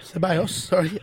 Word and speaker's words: Ceballos: Cabellos, was that what I say his name? – Yeah. Ceballos.